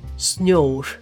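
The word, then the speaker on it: snjór: 冰岛语“ 雪”这个字呢，其实跟英文还蛮像的，但是它有一点点不一样哈。我现在要念，大家就听清楚了。它的发音是 “snow”。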